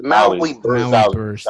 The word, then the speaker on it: Maui Burst: I'm butchering the name. Wahi Burst. So I guess it's a Hawaiian name. It's a pineapple flavor. – It's Maui Burst.